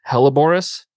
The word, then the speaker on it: hellebores: I might butcher this, hellebores.